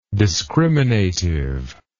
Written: discriminatively

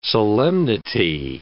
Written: solemnity

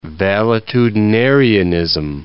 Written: valetudinarianism

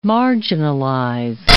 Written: marginalize

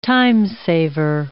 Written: time-saver

